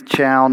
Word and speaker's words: Chal: My name is Chal Knox. Chal is short for Charles. It's spelled C-H-A-L.